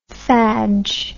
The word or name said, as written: fadge